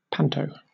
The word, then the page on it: panto: British English